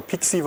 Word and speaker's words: PXE: Okay, man startet übers Netzwerk, das heißt PXE oder PIXI wird es oft abgekürzt oder ausgesprochen.